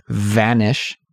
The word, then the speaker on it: vanish: So here, what my point is that the pronunciation of the V in vanish is very important. – Vanish. – Right. So be careful about that, everyone.